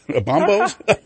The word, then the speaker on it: bonobo: Bonobos? What's a bonobo? I'm not sure what that is. – Right, and I had trouble learning how to pronounce that, too. I gather it's supposed to be bonobo.